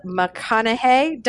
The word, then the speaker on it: McConnaughey: Today, I have the fabulous Liz McConaughey.